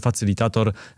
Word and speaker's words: facylitator: Ja kurczę, mam taki problem ze słowem polskim facylitator. Nie wiem do końca, czy ja to dobrze wymawiam, czy źle to wymawiam. – Facylitator jak najbardziej jest to poprawne poprawna forma i jest ok, jest ok.